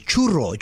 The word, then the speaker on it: churro: It is National Chorro Day! – Wait, you better say that right. – Chorro! – No, chorro is diarrhea in Spanish. It is not chorro. – That day. It is churro, churro.